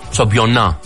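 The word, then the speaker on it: championnat: Ωραία, είναι χοντρό το σου. Εντάξει, κυρία Χριστοδούλου, το σου προφέρεται σαμπιονά, εντάξει.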